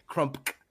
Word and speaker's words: Crump: Crump. – Oh, yeah, there's a K. – There's a K at the end. Crump. You had to pronounce the K.